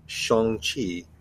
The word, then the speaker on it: Shang-Chi: And they were told by the handler beforehand, it's like, and just so that we're all on the same page, it is pronounced Shang-Chi and The Legend of the Ten Rings.